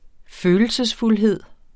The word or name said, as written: følelsesfuldhed